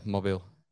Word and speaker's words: Mobile: Mobile. – Yeah, Mobile.